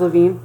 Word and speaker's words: Levine: Oh, it was Levine.